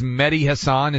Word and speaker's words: Mehdi Hasan: This guy's name is Mehdi Hasan.